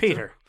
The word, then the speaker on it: Piotr: Uh, P- I can never say this right. Piotr. – Peter. Just say. Uh, Peter. Uh, we'll just say Peter.